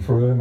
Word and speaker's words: Frome: It's spelled, if you want to look it up on the net, it's spelled F-R-O-M-E, but pronounced Frome.